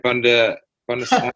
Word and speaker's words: Bundestag: Bundestag. Firstly, did I pronounce that right? Bundestag, yes. – Bundestag.